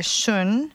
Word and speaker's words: xun: And in Chinese, we call this area shun, which is spelled X-U-N.